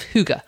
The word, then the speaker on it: hygge: This really embodies what I'm going to be talking about in the podcast today, because we're discussing the Danish concept of hygge, spelt H-Y-G-G-E, and pronounced, you might hear it pronounced hygge or hygge.